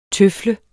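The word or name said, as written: TØFLEDE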